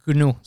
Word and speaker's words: GNU: GNU, se pronuncia en inglés.